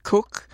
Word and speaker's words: Koch: Cook, whose name is spelled K-O-C-H, holds a Bachelor of Science degree in Electrical Engineering and Physics and a Master of Science degree in Electrical Engineering.